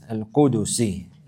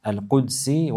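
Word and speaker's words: القدسي: It has come in the Hadith al-Qudusi. It's mentioned like this. You can also pronounce it with a sukun on the dal. Al-Qudsi wal-Qudusi, both of them are proper.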